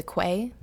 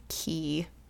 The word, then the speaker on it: quay: And Q-U-A-Y spells key.